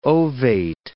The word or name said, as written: ovate